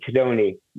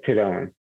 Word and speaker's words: Tadone: In Italy and half the people in the United States say Tadone. My family says Tadone.